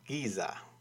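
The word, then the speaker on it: GZA: That man is known as Giza, or it's actually spelled as three capital letters, G-Z as he'd see it, A, but it's pronounced Giza.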